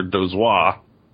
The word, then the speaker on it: Dozois: Martin and Gardner Dozois.